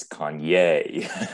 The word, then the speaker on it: Kanye: It's Kanye.